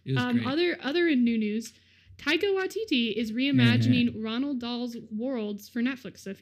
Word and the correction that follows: Roald Dahl: you don't know, or I pronounced that wrong. Roald Dahl.